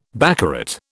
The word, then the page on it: baccarat: British English